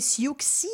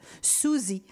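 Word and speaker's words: Siouxsie Sioux: J'entends souvent les gens essayer de prononcer Siouxi. Non, non, non, non, ça se prononce Suzy.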